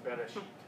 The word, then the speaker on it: Bereshit: It's a breathing mark. It's pronounced Bereshit. – Well. Okay, all the commentaries I had translated it as like R-Y-S-T, so I was not going to get there.